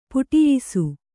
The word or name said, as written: ಪಠಿಯಿಸು